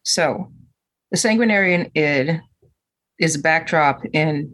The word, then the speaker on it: Sanguinarian: Brendan, like, lead us into the talk of the Sanguinarian Id. I said it right. Yes. – You got it.